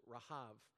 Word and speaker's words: Rahab: The final B in Hebrew would be pronounced as a V. They didn't have pronunciation of that letter, so Rahab.